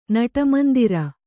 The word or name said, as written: ನಟಮಂದಿರ